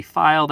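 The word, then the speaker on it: Feil: In both cases, my last name is spelled R-E-I-D. – And I am on Twitter and Letterboxd at Chris V. File. That's F-E-I-L.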